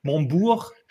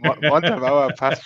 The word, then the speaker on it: Montabaur: Wo zur Hölle liegt Montabaur? Habe ich es richtig ausgesprochen? Montbourg? – Nee, Montabaur passt schon.